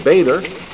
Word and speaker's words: Boehner: Representative John Boehner, some pronounce it Boehner,